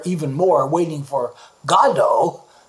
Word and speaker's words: Godot: Now, I first learned that as Waiting for Godot, but I found that that's a mispronunciation, that it's kind of an Americanization. The author, who was Irish and lived most of his life in Paris, actually intended it to be pronounced Godot.